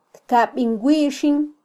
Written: tkabin wixin

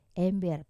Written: ember

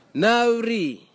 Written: nauri

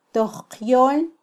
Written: tojqyol

